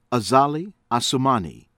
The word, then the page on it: Azali Assoumani: American English